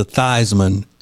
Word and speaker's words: Theismann: And he changed it to Theismann to rhyme with Heisman because he wanted to win the Heisman Award.